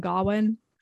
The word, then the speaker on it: Gawain: I just, I just did it and thought it looked like, Sean Harris is King Arthur pronouncing Gawain. – Garwin. He just doesn't know his nephew very well, so he can't pronounce his name.